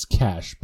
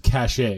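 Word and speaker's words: cache: It's cash, but yeah, thank you. – What's that? – It's cash, but thank you. – It's cash. – It's one of my pet peeves is when Americans pronounce it cachet.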